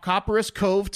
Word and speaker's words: Copperas Cove: On a driveway, it was a driveway chalk message in, can someone help me pronounce this? Because I feel like I should know it. Copperas, Texas? Caparis, Texas? – Copperas Cove. I never heard of it. – Caparis. – Okay. Copperas Cove, Texas.